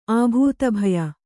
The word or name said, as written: ಆಭೂತಭಯ